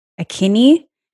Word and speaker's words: Akinyi: My name is Tina Akinyi, and my last name is spelled A-K-I-N for Nigeria, Y-I.